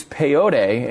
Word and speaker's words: peyote: I mean, even the Supreme Court has gone to the point of taking tribes that use peyote is that the way you pronounce it, Jeffy? – Yeah, that's correct.